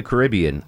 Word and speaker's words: Caribbean: Caribbean, if you're in the Caribbean, if you're from the Caribbean. If you're in the U.S., it's Caribbean.